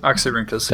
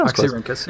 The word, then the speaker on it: Oxyrhynchus: Oxyrhynchus. – Did I say it right? – Yeah, oh, Oxyrhynchus. Yeah, that's right.